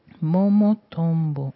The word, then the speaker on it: Momotombo: Espero haber pronunciado Momotombo correctamente. Momotombo, ok, sí.